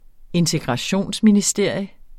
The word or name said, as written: integrationsministerium